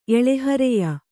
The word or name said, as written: ಎಳೆಹರೆಯ